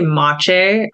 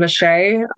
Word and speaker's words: Maciej: Cause I know you and I don't know, like I'd also like to ask about your partnership and, you know, relationship with Maciej. I'm saying the name right, correct? – Yeah, it's actually Maciej, I call him Maciej. Okay. It's just easier.